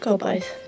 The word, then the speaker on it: Gobaith: How do you say it? Bryn? – Gobaith. – Gobaith. Bryn Gobaith.